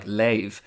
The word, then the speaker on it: leave: So, for instance, in some Dublin accents, words like leave, L-E-A-V-E, are sometimes pronounced like lave, which appears to correspond to Middle English pronunciations.